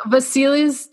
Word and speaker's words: Vasilis: So Vasilis definitely, because Vasili is also a, just a name for a male in the Russian culture. – Oh, see, I'm learning. I learned so much. – So you pronounced it right in Russian, at least.